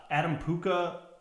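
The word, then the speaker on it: Adam Puchta: One of, Adam Puka? Is that how you pronounce it? The winery that's kind of up in the hills? – It is, yeah.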